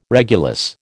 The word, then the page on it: regulus: American English